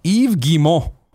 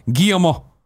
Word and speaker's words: Yves Guillemot: Yves Guillemot is pronounced Yves Guillemot, or alternatively, Guillemot. There's no S sound on Yves, no I sound, T sound, or nasal N sound in Guillemot.